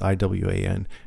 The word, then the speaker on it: Iwan: That guy, I don't know how to pronounce his name. Iwan? Rion? That's the actor. It's Iwan. Anyway, he's awesome, and I think he should get a lot of kudos for this role.